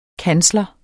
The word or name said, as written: kansler